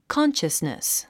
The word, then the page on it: consciousness: kάnʃəsnis; カンシャスネス